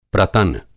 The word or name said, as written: प्रतन